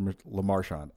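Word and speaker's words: Lemarchand: Huge shout out to Richard LaMarche. I'm probably saying his name wrong. It's Richard LaMarchand. I'm saying his name right. I always make it super French for no reason.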